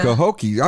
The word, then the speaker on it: Cahokia: at the Cahokia Mountains. I'm not even sure if I said that right. – Cahokia. – I was way off.